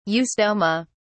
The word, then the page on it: eustoma: British English